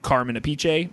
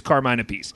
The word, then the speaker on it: Carmine Appice: The best I could tell, it's Carmine Appice. I was gonna guess like Carmen Appice, but no, I think it's Carmine Appice.